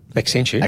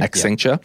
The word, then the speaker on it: Accenture: Accenture. – Accenture, yeah.